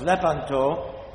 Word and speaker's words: Lepanto: and the correct pronunciation is Lepanto, not Lepanto. I was listening to some stuff on the internet today, and they kept saying Lepanto. I said, what is wrong with these people? They don't know anything about Italian. Anyways, Lepanto. The accent is on the E.